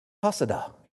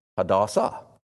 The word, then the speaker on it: Hadassah: The word in Hebrew is Hadassah. I think I said that wrong. I think it's Hadassah. Hadassah, I'm pretty sure.